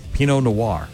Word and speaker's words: Pinot Noir: Peanut Noir. – Pinot Noir, I believe is how that's pronounced.